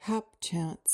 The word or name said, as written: hapchance